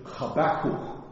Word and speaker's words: Habakkuk: Now, I think the proper way of saying his name is Habakkuk. That's the way you would say his name.